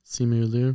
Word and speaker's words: Simu Liu: Simu Liu. Correct me if I said his name wrong. – I don't know. – I think that's right. – Simu Liu.